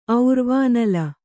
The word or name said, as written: ಔರ್ವಾನಲ